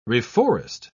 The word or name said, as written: reforest